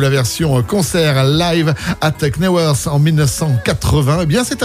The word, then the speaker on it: Knebworth: on prononce pas le K hein, quand c'est comme ça, c'est Knebworth.